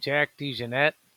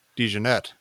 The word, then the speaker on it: DeJohnette: Jack DeJohnette. – DeJohnette.